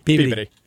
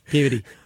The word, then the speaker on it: Peabody: Peabody. – Peabody. – Yeah, the rush to get through every vowel quickly.